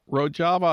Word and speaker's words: Rojava: And the New York Times reported last fall about this autonomous Kurdish region called Rojava. Am I pronouncing that right? – Rojava, an autonomous Kurdish region in Syria, which, according to the New York Times, is led by militant feminist anarchists.